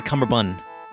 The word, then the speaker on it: cummerbund: I feel like it's a cummerbund. – A cummerbund? – I feel like it's not like a bunt cake.